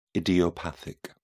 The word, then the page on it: idiopathic: British English